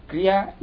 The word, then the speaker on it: Kriya: Kriya is a Sanskrit word, two syllables, Kri and Ya.